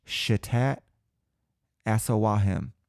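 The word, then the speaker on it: Chitat Assawahem: Shitat Asawahim, spelled C-H-I-T-A-T. Shitat A-S-S-A-W-A-H-E-M. Asawahim. Shitat Asawahim. That's my best pronunciation.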